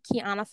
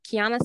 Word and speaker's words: Quiana: It's at the Kiana Fulton. It's spelled Kiana, spelled Q-U-I-A-N-A, and then my last name, F-U-L-T-O-N. So the Kiana Fulton on Twitter, also on Instagram.